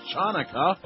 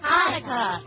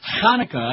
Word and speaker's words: Hanukkah: It's not pronounced Chanukah. It's Hanukkah. – Sponsored in part by Goldberg's Baked Goods. – No, actually, it's Hanukkah.